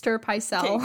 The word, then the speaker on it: Pycelle: And then he kind of addresses Maester Pycelle.